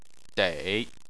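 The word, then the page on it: dei: tone 3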